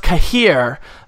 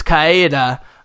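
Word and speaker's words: Cahir: So it looks like it's pronounced Kahir, but the way that the Peter Kenny reads it, I guess, is the Polish version, which is Kaeda. Kaeda.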